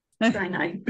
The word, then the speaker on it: Sinai: She lives in Sinai, Egypt. Have I said that right? – Sinai.